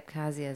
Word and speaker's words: Abkhazia: Yeah, I'd say Abkhazia is how you say that.